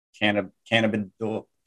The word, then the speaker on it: cannabidiol: It's just essentially CBD oil mixed with coffee, and CBD oil is cannabidiol, I think is actually how it's pronounced.